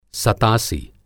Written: सतासी

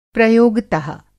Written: प्रयोगत:

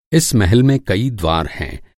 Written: द्वार

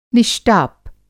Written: निष्टाप

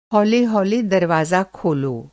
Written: हौले हौले

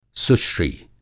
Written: सुश्री

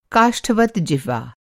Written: काष्ठवत्

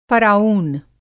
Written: फ़राऊन

